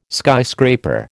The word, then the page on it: skyscraper: American English